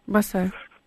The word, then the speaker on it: босая: Или босая, никак не знаю, как правильно ударение. – Босая. – Босая, да.